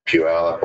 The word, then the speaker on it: Puyallup: Orlando, Michigan, Puyallup, Washington.